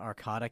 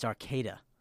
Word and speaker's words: Arcata: It's not Arcata, California. – Uh-huh. – It's Arcata.